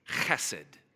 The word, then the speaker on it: chesed: I want to help you pronounce this today. So say esed. Now say, now look at the person next to you and say, and you smash it together and you have chesed.